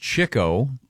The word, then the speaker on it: Chico: It's pronounced Chico. Really? – Spelled Chico. – I've never heard that one. – It's spelled Chico, but it's Chico.